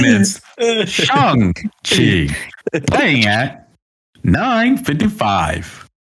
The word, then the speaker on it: Shang-Chi: And then yeah, the director came out for like Asian heritage and was like, "Hey, this is the actual pronunciation." And then even in the trailer, at the very end, it goes like, you know, buy your tickets for Shang-Chi now. It's like, okay, all right, confirmed. I've been saying it wrong.